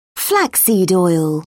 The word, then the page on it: flaxseed oil: British English